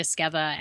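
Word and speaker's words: Esqueda: So it's just Ashley Esqueva. And my last name is, while confusing to pronounce, it's spelled E-S-Q-U-E-D.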